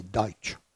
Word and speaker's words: Deutsch: C'est les Américains qui n'arrivent pas à prononcer Deutsch. C'est du Pennsylvania Dutch, en réalité.